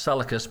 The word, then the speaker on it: Seleucus: We're going to look mostly at, his name's pronounced Seleucus, but it's the Seleucid Empire, and Ptolemy, who took the southern part.